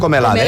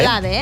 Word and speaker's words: Comelade: I acabem desitjant un bon dia al músic Pascal Comelade. Com es pronuncia? – Comelade. – Comelade, eh? – Sí, sí.